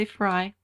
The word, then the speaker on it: Frey: Most people say Fry. I think it's Fry. – Yeah, although it's spelled like Prey, P-R-E-Y, so.